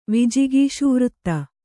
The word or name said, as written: ವಿಜಿಗೀಷುವೃತ್ತಿ